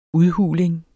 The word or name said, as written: udhuling